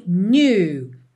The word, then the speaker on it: knew: E lá na primeira linha, the night we met, I knew. Knew. O K é mudo, tá? E esse N-E-W, ele não é assim muito desenhado new, new, tá?